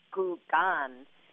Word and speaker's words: Kukan: So the word kukan is really a Chinese word that in Mandarin is more, pronounced more like gugan, and it's made up of two different Chinese characters.